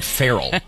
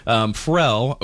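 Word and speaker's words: Pharrell: I say it wrong every time. I call him Farrell. It's very hickish of me. Farrell Williams, and he's got a little song there from the movie Despicable Me 2, huh? – Mm-hmm.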